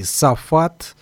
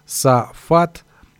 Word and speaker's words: Saphath: Группа называется, если в нашем произношении Сафат, Сафат, но пишется латинскими S-A-P-H-A-T-H. -A -T -H.